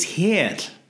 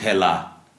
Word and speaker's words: Hel: It's Hel. – Just Hel. – Hel, not Hela, which is also Loki's daughter.